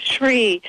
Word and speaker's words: Zrii: Sri, it's first of all, it's spelled Z-R-I-I, and I had received information from lightworkers in Sedona describing this liquid nutritional drink.